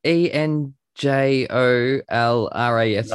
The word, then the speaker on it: Enjolras: Isn't it like E N J O L R A S?